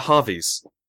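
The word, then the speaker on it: Hervey: The Harveys. Britain's most scandalous aristocratic family. So it's spelt Hervies.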